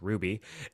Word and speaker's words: RWBY: Ruby, pronounced Ruby, it's spelled R-W-B-Y. – R-W-B-Y.